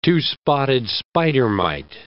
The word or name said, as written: two-spotted spider mite